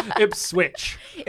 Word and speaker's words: Ipswich: We're shooting a wedding in Ipswich. Did I say it right? – No. – Okay. – Ipswich. – Ipswich. – Yeah. Okay. – Like a sandwich.